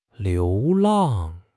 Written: 流浪

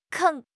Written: 坑

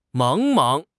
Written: 茫茫

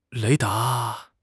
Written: 雷达

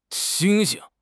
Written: xing1 xing5

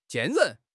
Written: 坚韧

